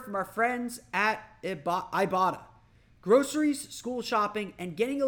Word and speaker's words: Ibotta: I forget how they want us to pronounce it, but it's Ibotta. That's Ibotta. You can earn cash back on every shopping trip.